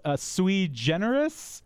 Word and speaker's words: sui generis: Sui generis? – You pronounced it correctly.